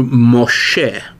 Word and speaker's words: Moshe: Another thing that's interesting is that uh, the names are given in the Hebrew pronunciation, not the English, which is to say that um, Moses is in Hebrew Moshe and uh,